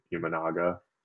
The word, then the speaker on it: Imanaga: I guess you don't necessarily know what you're getting from, and forgive me if I pronounce his name wrong, but Imanaga. Did I get that right? – You got it, man.